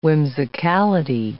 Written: whimsicality

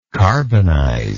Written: carbonizer

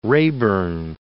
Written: Raeburn

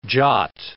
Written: Jat

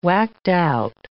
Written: whacked-out

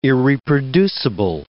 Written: irreproducible

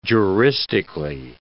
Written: juristically

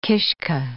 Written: kishke

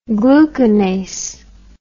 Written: glucanase

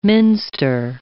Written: minster

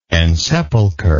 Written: ensepulcher

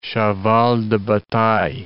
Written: cheval de bataille

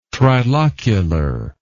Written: trilocular

